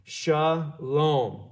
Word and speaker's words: shalom: And so this is pronounced shalom. This is like a SH sound. That's a L. That's a O. And that's an M.